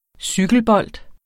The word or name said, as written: cykelbold